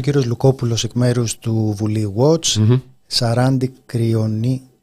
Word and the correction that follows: Κρυώνα: αν το τονίζω καλά... – Κρυώνα. – Κρυώνα.